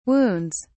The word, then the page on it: wounds: British English